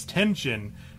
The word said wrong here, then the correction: Tenjin: You say Tenshin? – No. Tenjin. – Tenjin.